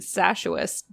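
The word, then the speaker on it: Sachuest: So it's pronounced, as far as I can tell by her pronunciation, Sashuest Beach.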